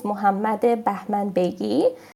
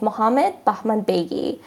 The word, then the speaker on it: Mohammad Bahmanbeigi: The name with the Persian pronunciation is Mohammad Bahman Begi. So if I want to say it in the English pronunciation, is Mohammed Bahman Begi.